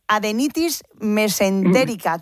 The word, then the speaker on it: adenitis mesentérica: Bueno, queremos hablar con usted de la, a ver si lo digo bien, adenitis mesentérica. ¿Qué es y qué síntomas presenta? – Pues bueno, sí, lo has dicho bien.